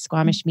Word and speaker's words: Squamish: So go check her out, guys, Squamish Medium.